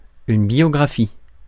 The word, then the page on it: biographie: [bjɔ.ɡʁa.fi]; France